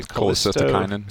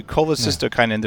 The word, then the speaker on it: Cholecystokinin: And it's a this is the hardest one to say. – Cholecystokinin. – Thank you. Cholecystokinin. There's a syllable in the middle I always get wrong.